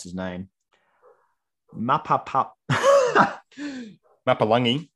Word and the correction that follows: Mapapalangi: Mapapalangi. – You're missing out a pa in there.